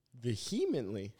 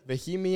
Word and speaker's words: vehemently: Vehemently? Vehemently? – Well, it's not vehemently, it's vehemently. – Vehemently. – You don't have to pronounce the H like that.